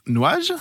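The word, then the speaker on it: NJ: N J, qui se prononce Nuage.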